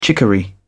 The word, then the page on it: chicory: British English